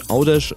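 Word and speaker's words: Għawdex: G-H fällt also ganz weg am Anfang. Dann kommt ein A, das bleibt auch so. Dann ein W, das sich U ausspricht. Dann haben wir Au. D und E bleiben, haben wir Au, D. Folgt zum Schluss ein X. Da haben wir gelernt, das ist ein Sch ausgesprochen. Macht aus Gavdex Audesh.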